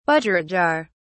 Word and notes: budgerigar(s): British English

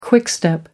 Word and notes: quickstep: American English

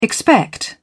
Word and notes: expect: British English

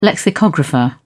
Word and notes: lexicographer: British English

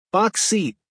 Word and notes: box seat: American English

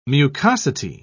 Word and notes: mucosity: British English

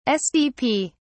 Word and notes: sdp: British English

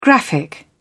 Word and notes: graphic: British English

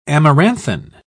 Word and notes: amaranthine: British English

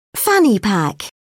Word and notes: fanny pack: British English

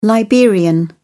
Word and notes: liberian: British English

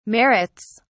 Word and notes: merits: British English

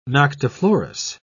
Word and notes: noctiflorous: British English

